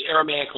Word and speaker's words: Aramaic: It's Aramaic? – The Aramaic language, yes.